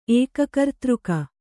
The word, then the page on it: ಏಕಕರ್ತೃಕ: Kannada